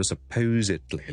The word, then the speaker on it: supposedly: 上一集同大家讲咗 supposedly 呢个字嘅发音，嗰、那个 supposed 咧个 s e d 咧，我系要读埋个 z 出嚟嘅。